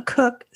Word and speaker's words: Cooke: You're Cook, K-O-C-H, and I'm a Cook, C-O-O-K-E, right? – Yes, right, right. And, you know, it will help people, though, if people are ever curious how to pronounce K-O-C-H, it is the same way as yours. – Exactly.